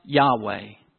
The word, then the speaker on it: Yahweh: But in the English, we have added vowels, and it's pronounced Yahweh. Yahweh. Yahweh is God's proper name.